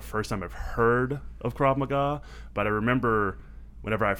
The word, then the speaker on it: Krav Maga: So I've always heard of Krav Maga. That's mm-hmm. how you pronounce it, correct? – Yep.